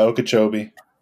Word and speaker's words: Okeechobee: Is that how you say it? – Yeah, Okeechobee.